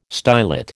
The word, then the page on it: stylet: American English